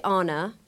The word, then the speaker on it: Anna: You pronounce it Anna.